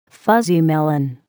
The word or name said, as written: fuzzy melon